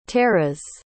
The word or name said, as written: taras